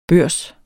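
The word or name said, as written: børs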